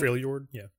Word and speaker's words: Freljord: And then Freljord, am I saying that right? – Freljord, yeah.